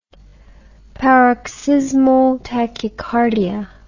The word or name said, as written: paroxysmal tachycardia